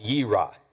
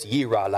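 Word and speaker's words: yirah (יִרְאָה): It's pronounced yirah. It looks like yer or yare, but it's yirah, like yee-haw, only yirah.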